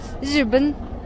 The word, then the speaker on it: 日本: So it's spelled R-I, but it's actually Z, just Z, 日本.